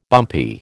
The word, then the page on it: bumpy: American English